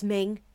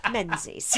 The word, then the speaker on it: Menzies: It's like how Menzies is, that's Ming. No, it isn't. Shut up. – Yeah. – Does that mean that the shop was called John Mingus? – Yeah, maybe. – What about Tobias? Is it Tobias Mingus? – No, Tobias is Thomas. That's how you say it. – Oh. – No, it isn't. – So it's Thomas Mingus, the actor? – Thomas Menzies.